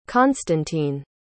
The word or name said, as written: konstantin